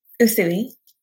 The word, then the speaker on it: Usui: How do you pronounce his name? – Usui.